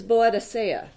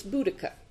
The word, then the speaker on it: Boudica: And as a medievalist myself, I was fascinated that the way that I've always heard it mispronounced, it's still being mispronounced, is Boadicea. Or there's Boadicea, there's Boadicea. They've since decided that it's Boudica.